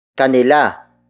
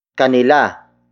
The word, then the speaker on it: kanila: Kanila. Kanila. Kanila is spelled as K-A-N-I-L-A.